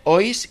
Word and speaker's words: Hoys: Pues nada, muchas gracias también David Ois, que se escribe H-O-Y-S,